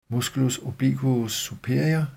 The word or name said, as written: m. obliquus superior